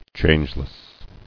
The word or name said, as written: Changeless